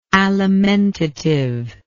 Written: alimentativeness

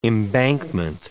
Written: embankment

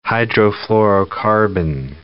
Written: hydrofluorocarbon

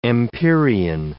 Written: empyrean

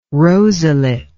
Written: rosulate

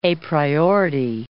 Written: apriority

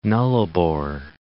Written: Nullarbor Plain

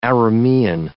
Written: Aramaean

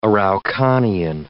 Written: Araucanian

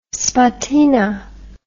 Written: spartina

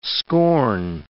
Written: scorn

